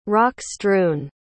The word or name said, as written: rock-strewn